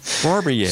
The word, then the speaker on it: Barbier: Butch Bowers and is it Deborah Barbier? – Mm-hmm. – I think that's like two South Carolina. – You're attorneys. – Asking me how to pronounce a name. Yeah. Wow. Yeah. That's right. Yeah. As a caveman once said, yeah. Barbier.